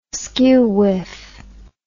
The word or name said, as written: skewwhiff